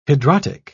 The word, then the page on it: hidrotic: British English